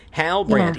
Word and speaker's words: Halbrand: I think it's Halbrand. – Halbrand. Halbrand. Yeah. If you break it down, Halbrand, like a brand of Hal's.